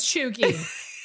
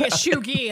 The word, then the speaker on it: cheugy: I don't want to goddamn even saying Ashugi. – It's like what it, like I'm like pronouncing it in an Asian way, and it's definitely not that, right?